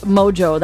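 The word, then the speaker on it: Mongeau: But Mojo, that's how you pronounce it.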